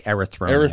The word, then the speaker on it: Erythronium: So it's, how do you pronounce this, friend? It's erythronium? – I would say erythronium. – Erythronium, yeah.